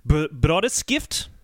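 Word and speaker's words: Brødreskift: They had a drummer, Eric, and I'm going to butcher his last name, I apologize, Brodeskift. – That was nice. That was nice.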